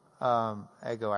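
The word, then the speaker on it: Ego (ἐγώ): Ego actually is a long O, sorry.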